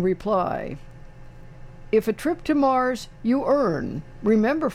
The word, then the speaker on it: Moers: It's spelled M-O-E-R-S, but it's pronounced Mars, I guess, better than, you know, some places in Pennsylvania.